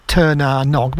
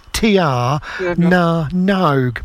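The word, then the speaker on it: Tír na nÓg: I thought they were Turner Nog, but apparently in latter years I learned it should be pronounced Tiana Nogue, but it's Turner Nog.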